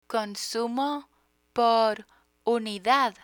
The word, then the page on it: consumo por unidad: Spanish